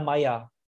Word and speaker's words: Meyer: Yeah, the German pronunciation would be Linda Meyer.